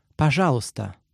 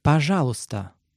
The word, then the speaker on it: пожалуйста: In Russian, please is пожалуйста. Пожалуйста. Let's break it down by syllable.